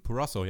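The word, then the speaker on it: Purrazzo: Ja, aber die die die die die Purasso oder wie spricht man sie aus? – Ähm, mhm. – Ähm, Purasso, ja.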